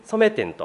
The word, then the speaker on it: 染め店: なのでいつもギャラリーに電話させていただくと、はい、染め店と、まあ、展覧会のね、なんとか店というふうに、取られると思うんですけれども、染め店というふうに発音していただけるとありがたいですね。